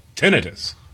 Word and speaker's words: tinnitus: Yeah, but the top American pronunciation is... – Tinnitus. – That is preferred in the medical and scientific community, also highly common in British English. So we're going to stick with tinnitus.